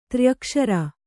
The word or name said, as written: ತ್ರ್ಯಕ್ಷರಿ